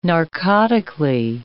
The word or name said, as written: narcotically